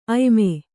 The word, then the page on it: ಆಯಾಮ: kannada